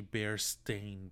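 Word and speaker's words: Berenstain: So, I thought it was Bearstein. Uh-huh. The Bear Stein Bears. Uh-huh. Berenstein. But it's spelled with an A. It's actually Bear Stain Bears.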